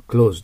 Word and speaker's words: clothes: es は clothes と発音しなくて、閉じるの動詞の clothes と同じに発音してください。 clothes です。